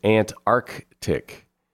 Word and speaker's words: Antarctic: You're supposed to pronounce that hard C in the middle. It's Antarctic.